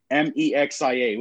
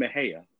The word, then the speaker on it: Mexia: You know, yeah, I can it's, imagine it's, it's, it's spelled M E X I A, which you would say it's like Mexia, but it's actually Mahia.